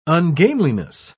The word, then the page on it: ungainliness: British English